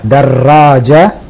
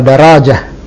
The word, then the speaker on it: darraja: Darraja pakai tasdid. Jadi tidak benar kalau daraja. Yang benar adalah darraja dan tertulis di situ ada tasdid.